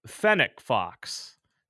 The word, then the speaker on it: fennec fox: So I'm curious about this fennec fox. Did I pronounce that correctly? – Yes, you did.